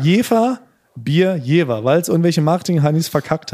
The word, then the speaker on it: Jever: Das Bier aber tatsächlich Jever mit W, wie Wilhelmine.